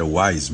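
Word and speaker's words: Houaiss: Bom, de fato, o dicionário WISE, que ele diz aqui, dicionário WISE impresso, o dicionário WISE, é sempre bom a gente dizer, as pessoas se matam para pronunciar WISE, não é nada disso, é WISE mesmo, escreve-se com H-O-U-A-I-S-S, é o sobrenome do Antônio WISE,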